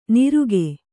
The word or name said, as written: ನಿರುಗೆ